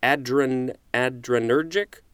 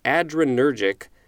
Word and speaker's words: adrenergic: But it's spelled A-D-R-E-N-E-R-G-I-C. So it looks like it's A-D-R plus energetic, but I believe it's pronounced adrenergic.